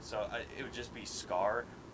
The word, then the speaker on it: Skaar: I mean, in the Planet Hulk series, or the movie, he has two kids with this woman, and one of them's named Scar, but it's spelled like S-K-A-A-R.